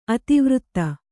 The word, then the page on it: ಅತಿವೃತ್ತ: Kannada